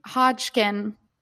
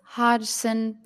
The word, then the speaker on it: Hodgson: It's Francis Hodgson. Francis. – Oh, God. – We've been saying so Hodgkin. We've been putting in a magic K. – Hodgkin. There is no Hodgkin there. It's Francis Hodgson Burnett.